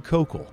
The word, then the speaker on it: Koukl: The name of this great book is Tactics by Greg Kokel. Incidentally, that's spelled K-O-U-K-L, and it's available from Zondervan Publishers.